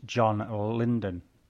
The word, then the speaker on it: John Lydon: However, it is perhaps worrying that the chief character witness for Baker, produced in the opening few minutes, is the pop-eyed John Lydon.